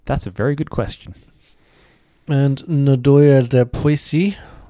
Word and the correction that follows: Noyau de Poissy: which is oh sorry, Noyau de Poissy, which is apricot flavored.